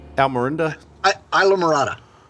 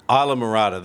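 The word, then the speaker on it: Islamorada: Isla Mirada. – Isla Mirada, there we go.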